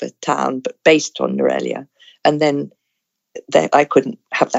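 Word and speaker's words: Nuwara Eliya: It's based on a town in the Hill Country called Norelia, but it's not spelt that way. It's spelt Nuara Elia.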